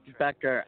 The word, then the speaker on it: Becker: And how do you pronounce your last name? – Becker, as the TV show back in the 90s.